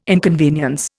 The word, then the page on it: inconvenience: Indian